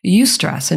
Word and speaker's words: eustress: There's actually a word for good stress, if you can believe it, and it's called eustress, and it's spelled E-U-S-T-R-E-S-S.